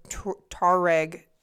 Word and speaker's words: Tuareg: They weren't tourists. He stumbled into a group of Tuareg herders. – You didn't say it like that. It sounded like you said tourist.